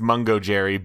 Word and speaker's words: Mungojerrie: I have confirmed the cat's name is pronounced Mungo Jerry